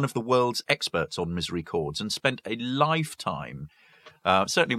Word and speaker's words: misericords: So Misery Chords is spelled MISERICORDS.